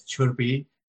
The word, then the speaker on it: Chirpi: I mean, in typical Nepali uh, pronunciation, it's Chirpi and Durka.